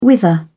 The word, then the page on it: wither: British English